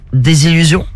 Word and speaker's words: désillusion: Là, c'est une délusion, une délusion, ça se dit? – Désillusion. – Désillusion, voilà. Oui. J'ai oublié une syllabe.